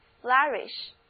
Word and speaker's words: flourish: 注意字母组合 ou 的发音不再念 our，而是啊，就是这个音 flourish，跟我念两遍 flourish flourish， very good，非常好。